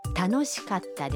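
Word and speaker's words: تانوشیکاتا (楽しかった): در کلمه کاتا بین کا و تا یک مکس خیلی کوتاه داریم. یعنی در واقع صدای ت با تشدید تلفظ میشه. – تانوشیکاتا دس.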